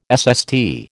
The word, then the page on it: SST: American English